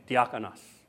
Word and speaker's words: diakonos: Did I say that right? Okay, diakonos, which is where we get our word deacon. Thank you. Greek speaker back there. Two Greek speakers. So it's correct.